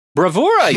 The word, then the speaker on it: bravura: Wait, I learned the word. Bravura? – Bravura, you did great. – Bravura. – Yeah. – I was pronouncing it bravura.